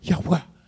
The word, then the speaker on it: Yahweh: It, it sounds like Yahweh. You know, like that's like that's like the, the closest term and the, the, the, the most honorific term that we have for God is this, you know, Y-A-H-W-H.